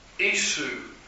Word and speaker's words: Isu: The next word is Isu, is how you pronounce that.